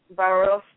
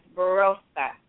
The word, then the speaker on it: Bharosa: And trust in Hindi is Bharosa. And I'm going to say it again, Bharosa. And that is spelled B as in boy, H-A-R-O-S-A, Bharosa. – Bharosa? – Bharosa. So it's think about it like bar, rose, Bharosa. – Barossa. – Mm-hmm. Yeah, exactly. You're trying not to pronounce that H, but you're still pronouncing it. So Barossa.